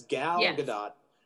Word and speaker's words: Gal Gadot: Well, it is definitely Gal, right? It's Gal Gadot.